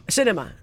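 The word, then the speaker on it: Sinema: I will give you Liz Cheney, and I will give you Mitt Romney for Manchin and Seneca. What's her name? Seneca? – Seneca. – Not Seneca. I'm saying her name wrong. – Yeah. – Cinema. I will take her.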